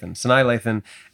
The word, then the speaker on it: Sanaa Lathan: At number three, we have the 2000 basketball romance Love and Basketball, starring Sanaa Lathan. I'm not sure if I'm saying that correctly. – Sanaa Lathan, and Omar Epps.